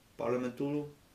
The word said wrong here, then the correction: Palatul Parlamentului: Palatul Parlamentului.